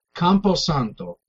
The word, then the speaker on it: Campo Santo: So, Campo Santo.